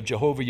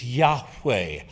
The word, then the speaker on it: Yahweh: How did the Hebrews pronounce that since they don't have a J or a V in their alphabet? It would have been impossible, literally, for the Hebrews to pronounce it Jehovah. But because of the influence of the German language on ours, we've substituted J's and V's where Y's and H's exist. His name is Yahweh. Say Yahweh.